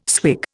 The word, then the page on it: swig: Indian